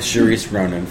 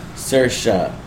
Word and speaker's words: Saoirse: Having seen none of these movies, I'm going with Charisse Ronan for a little while. – Because he's hungry. – Charisse Ronan. So, how do you actually pronounce it? – Saoirse.